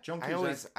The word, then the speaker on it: Joan Cusack: Joan Cusack. Am I saying that right? – Yeah, Joan Cusack.